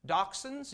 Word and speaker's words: dachshund: Dachshunds, you know, the little, I know, everybody calls them wiener dogs because they look like hot dogs, but dachshunds, or some people pronounce it dachshund, that's a German name, okay?